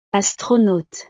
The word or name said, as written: astronaute